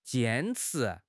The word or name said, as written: jian1 chi2